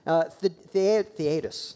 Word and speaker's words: Theudas: He first highlights Theudas, that's how you say it.